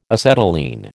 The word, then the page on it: acetylene: American English